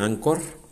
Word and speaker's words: Anchor: Se escribe Anchor, pero se pronuncia Anchor.